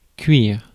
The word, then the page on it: cook: /kʊk/